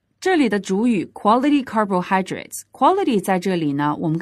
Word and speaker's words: carbohydrates: 但是呢我们在这句话当中的 quality 呢是做的它的形容词形式等同于 excellent, 修饰后面的 carbohydrates, carbohydrates, 这个词我们来说一下它的意思叫做碳水化合物它的重读音节呢是落在哪个音节上呢大家仔细听一下。 carbohydrates, carbohydrates, 没错就是 hydrate, high, 第三个音节的地方。